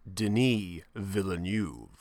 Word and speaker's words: Denis Villeneuve: It's like, it's pronounced Denis Villeneuve.